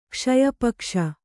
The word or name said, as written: ಕ್ಷಯಪಕ್ಷ